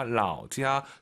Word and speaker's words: 老家: 老家. 삼성 일성은요, 선생님을 떠올리세요. 老师 발음하고 똑같잖아요. 老家. 발음이 똑같은 게 아니고 성조가 똑같은 거죠.